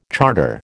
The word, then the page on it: charter: American English